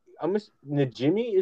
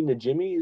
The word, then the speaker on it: Najimy: Najimy is how she. Kathy Najimy is how it's pronounced.